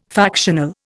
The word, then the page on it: factional: Indian